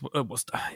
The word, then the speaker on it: Worcester: Yeah, it's Worcester.